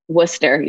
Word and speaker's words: Worcester: I know you grew up in, you pronounce it Worcester, Massachusetts? Is that right? – Worcester, yeah.